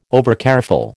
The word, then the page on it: overcareful: American English